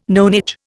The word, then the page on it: nonage: Indian